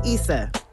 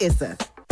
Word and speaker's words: Issa: And right now I'm using Issa's voice, which is pronounced Issa, not Issa.